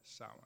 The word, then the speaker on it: Samhain: And the name in Ireland is an even older one that can be traced back over 2,000 years, and it's called Samhain. It's not spelled that way, but that's the way they pronounce it.